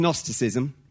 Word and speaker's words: Gnosticism: It's Gnosticism. Begins with G-N, silent G.